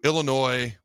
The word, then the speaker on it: Illinois: All right, when it comes to Illinois, where the S is silent, in Nebraska, here are two keys that stand out to me right now, early on in the week.